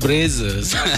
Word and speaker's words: Brazzers: Brazzers.